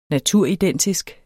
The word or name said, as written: naturidentisk